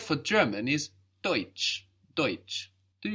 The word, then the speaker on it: Deutsch: Deutsch is spelled D E U T S C H. So EU in German gives us oi. It gives us this oi sound.